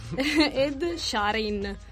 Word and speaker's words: Ed Sheeran: Come si dice? Ed Sheeran. Lo saprà sicuramente Armand dire meglio di noi. – Sì, non so se pronunciarlo meglio, Armand. – Dai, dacci, sì, dacci detto... il titolo della canzone, Armand. – Ha detto che va bene, è quella, è giusto.